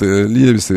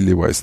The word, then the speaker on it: Levi's: Значит, Левис, это и Левайс, там два произношения, нормально, все в порядке.